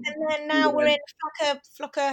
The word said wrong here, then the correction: Flachau: how do you say it? – It's Flachau.